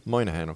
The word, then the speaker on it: Moynihan: Moynihan, okay.